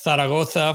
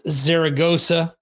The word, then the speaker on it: Zaragoza: Okay, so we went to, way back when, we went to see Motley Crue in a city called, in Spanish it's pronounced Zaragoza. For the English speaking crowd, it's Zaragoza.